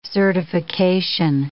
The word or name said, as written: certification